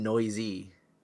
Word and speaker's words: Neuse: First name is Sheldon. N E U S E. – News. No. – Nice. Noise. – Noise? It's got to be Noyce, right? – It's Sheldon Noisy.